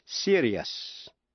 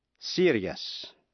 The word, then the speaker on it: serious: T-I-O-U-S phát âm là shus, như cautious, cautious, ambitious, ambitious và anxious, anxious. Còn nếu trước những tiếp vĩ ngữ I-O-U-S không phải là phụ âm C, T hay X, thì đuôi I-O-U-S phát âm thành hai âm tiết, tức là hai syllables, yes. Thí dụ, serious, serious.